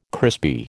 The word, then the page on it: crispy: American English